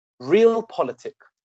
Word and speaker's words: realpolitik: The word is realpolitik. The word is spelled as one word, R-E-A-L-P-O-L-I-T-I-K.